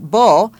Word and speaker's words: beau: Beau. A beau is a boyfriend, and it's spelled B-E-A-U.